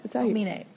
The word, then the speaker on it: Omine: Omine.